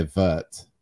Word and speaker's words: wort: But like, listen, so if you're going to pronounce all the words with the appropriate accent, we shouldn't say wort, we should say vert, right? And I don't know. I don't think I could bring myself to say vert. – But do you say wort? – I think I say it more like W-E-R-T.